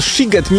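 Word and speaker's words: Sziget: Czy możemy ustalić, czy ten siget, mimo że jest przez SZ, to jednak jest sigetem czy sigetem? – Oczywiście, ponieważ w języku węgierskim szy czyta się jak sy, a sy czyta się jak szy.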